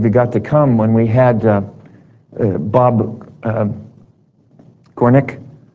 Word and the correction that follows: Cornuke: come. And boy, I already got his name wrong, didn't I, Bill? Anyway, it's something like that, isn't it? Cornuke, thank you, Cornuke. Yes, there it is.